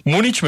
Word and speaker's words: Múnich: ¿Múnich mejor o Múnich? – Eso es es muy gracioso porque hay muchas formas de pronunciar, pero oficialmente en español se debe decir Múnich. Pero casi nadie lo pronuncia así.